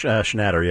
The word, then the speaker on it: Schnatter: Schnatter, yeah.